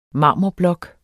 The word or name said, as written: marmorblok